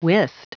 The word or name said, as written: wist